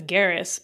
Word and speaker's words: Garrus: Well, I find that there's the character, is it Jairus? Is that how you pronounce it? Or... – That sounds so good. I normally think of him as Garrus, but okay, Garrus.